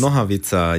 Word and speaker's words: Nohavica: Den nächsten Song, äh, den ich hier anschmeißen äh, würde, ich hoffe, ich spreche ihn richtig aus, von Jaromir Nohavika äh, Jacek. Ja. Richtig ausgesprochen? Völlig falsch ausgesprochen. – Verdammt. Dann deswegen ja, überlasse ich dir Noha, das jetzt. Nohavica.